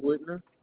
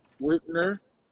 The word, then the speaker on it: Whitner: My name is Devontae Whitner. – Devontae Whitmore? – Whitner. – Whitner? – W-H-I-T-N-E-R. – W-H-I-T-E-N-R.